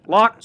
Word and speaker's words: Lach: But, man, is it Lachey, Lach, Lack, Lock? How does McKinney spell it? Lake, Lock, L-A-C-H. Lock McKinney.